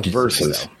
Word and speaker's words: Verzuz: How do you say this company's name? Verzuz? Versus? I'm guessing it's just Versus. It's with Versus.